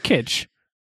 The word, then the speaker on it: Kitsch: Well, it's Kitsch. It's Kitsch. Okay. How is that hard? – Because I keep wanting to like overpronounce the S.